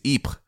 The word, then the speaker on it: Ypres: Interestingly, most people told me it was pronounced Ypres. And I just want to say that's a beautiful British mangling of French that no French person would understand. Ypres to this town. The French pronunciation is Ypres.